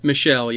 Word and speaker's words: Michel: We're going to take away Sonny Michel. Or Michelle, however you pronounce his name. Is it Michelle? – Michelle, yeah.